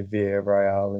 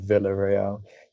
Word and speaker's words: Villarreal: Yeah, well, my first thing to know is, of course, Orlando's the kind of guy i say Villarreal instead of Villarreal. You could just see the double L being pronounced as a Y.